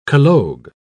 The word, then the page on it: collogue: British English